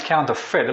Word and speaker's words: counterfeit: Okay, it's actually pronounced counterfeit. It looks like fake, but it's actually just pronounced like F-I-T, so that'd be counterfeit.